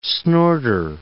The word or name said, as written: snorter